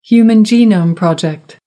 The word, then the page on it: human genome project: American English